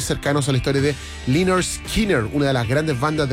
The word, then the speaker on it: Lynyrd Skynyrd: Se pronuncia Lynyrd Skynyrd, es un disco sensacional, de verdad que recoge lo mejor de esa vertiente del country rock.